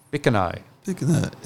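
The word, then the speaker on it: Bicheno: how do you say that? Bishano? Yeah, it's a town on the east coast of Tasmania. Or Bickano. – Bickano, yeah.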